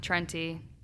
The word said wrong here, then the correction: Trenta: Is it how you say it? Trenta? – Or Trenta, yeah.